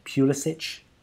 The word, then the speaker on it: Pulisic: They've got that young talent, Pulisic.